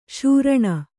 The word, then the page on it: ಶ್ರೋಣ: kannada